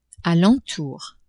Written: a l'entour

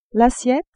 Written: assiette

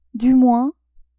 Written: du moins

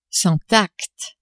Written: sans tact

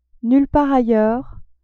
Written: nulle part ailleurs

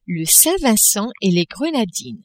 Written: Saint-Vincent-et-les-Grenadines

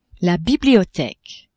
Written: bibliotheque

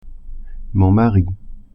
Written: mari

